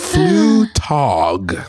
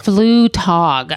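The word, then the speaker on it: Flugtag: Flutog. – Flutog.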